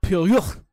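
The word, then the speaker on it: Pierrot: Pirate Pie. – Piero. – Piero. It's pronounced Piero.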